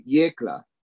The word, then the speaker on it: Yecla: YECLA.